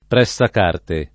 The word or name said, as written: pressacarte